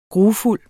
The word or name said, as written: grufuld